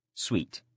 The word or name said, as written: sweet